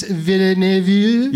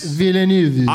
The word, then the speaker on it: Villeneuve: Well, that Villeneuve, how do I say his last name? – Denis Villeneuve. – Yes. – Villeneuve.